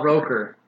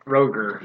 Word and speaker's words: Roker: Al Roker, not Roger.